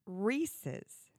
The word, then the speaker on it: Reese's: Tyler, how do you say the actress whose last name is Witherspoon? – Reese. – Okay, so it's Reese's.